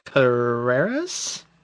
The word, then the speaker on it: Carreras: That one was Michael Carreras? – Mm-hmm. – How you say it, correct? – Yes, yeah, yeah.